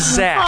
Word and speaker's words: Zax: And also, in true Atlas form, you mispronounced his name, which is perfect. I actually enjoyed that a great deal. His name is Zax. – Aww. – His name is Zax. Z-A-X.